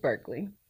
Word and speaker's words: Berkley: Showgirls stars Elizabeth Berkley.